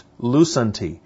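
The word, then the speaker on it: lusanti (λύσαντι): Now, the word released is pronounced lusanti. And if you look in your notes, it's spelled, transliterated in English, L-U-S-A-N-T-I.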